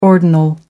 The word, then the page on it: ordinal: American English